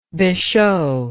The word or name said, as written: beshow